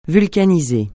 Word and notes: vulcaniser: francais

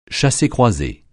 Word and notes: chassé-croisé: français

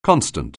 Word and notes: constant: anglais

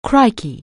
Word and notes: crikey: anglais

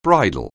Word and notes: bridal: anglais

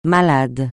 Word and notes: malade: francais

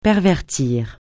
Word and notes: pervertir: francais

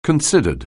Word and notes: considered: anglais

